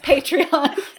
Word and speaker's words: Patreon: But seriously, how do you pronounce it? – It's Patreon.